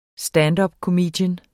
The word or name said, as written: standupkomiker